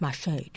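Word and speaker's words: mâche: We talked about mash, and I've heard it pronounced mache, too, but I suppose that's if you just want to be a little more fancy.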